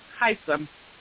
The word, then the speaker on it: Hisam: How is it pronounced? – Hisam? I just say Hisam.